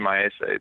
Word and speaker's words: Maese: There's a pitcher named Justin Maese that uh, is that the I believe that's the pronunciation, right? – I'm pretty sure that is right.